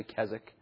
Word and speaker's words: Keswick: And the Higher Life movement, which was begun during his day, the Keswick, often pronounced Keswick. It's spelled Keswick, but it's actually Keswick.